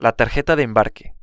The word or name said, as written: la tarjeta de embarque